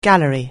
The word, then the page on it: gallery: anglais